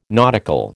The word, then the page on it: nautical: American English